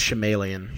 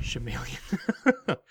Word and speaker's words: Shyamalan: Night Shyamalan twist to it, but... – It's pronounced Shyamalan. – Shyamalan.